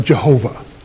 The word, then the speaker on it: Jehovah: Now, we, in order to, and the Jews, the Hebrews, in order to make it pronounceable, added some vowels to YHWH and made it Yehovah. We now pronounce that Jehovah.